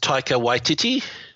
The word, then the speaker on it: Taika Waititi: Sure, it's pronounced Taika Waititi. – Okay, Taika Waititi.